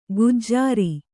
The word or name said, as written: ಗುಜರಿ